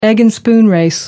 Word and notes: egg and spoon race: American English

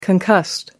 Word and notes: concussed: American English

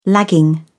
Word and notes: lagging: British English

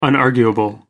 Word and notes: unarguable: American English

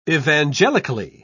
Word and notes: evangelically: British English

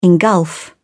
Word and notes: engulf: British English

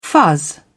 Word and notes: fuzz: British English

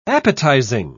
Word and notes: appetising: British English

